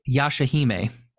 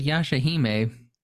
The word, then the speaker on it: Yashahime: Sorry, what was it? Yoshihime? – Yashahime. – Oh, I'm pretty sure you said Yosho. – Nope, I'm pretty sure I said Yashahime.